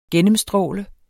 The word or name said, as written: gennemstråle